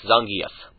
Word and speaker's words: Zangief: Zangief is probably the most common, although officially, if you want to, you know, correct, it's Zangief.